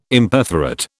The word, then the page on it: Imperforate: British English